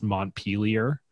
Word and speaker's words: Montpelier: the capital of Vermont is pronounced Montpelier. – Yeah. – You know, I think you probably think it is like, it's, I mean, come on, it's named after Montpellier.